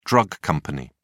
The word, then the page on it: drug company: British English